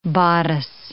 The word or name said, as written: bodice